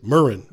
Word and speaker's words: murrain: And by the way, I looked up the pronunciation. It's murrain. That's what they said.